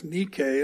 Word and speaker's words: Nike: The name Nicolaitan is made up of the name of the Greek goddess of victory, Nike, or actually it's pronounced Nike, and the word for people, which is Laos, Nike Laos, Nicolaitans.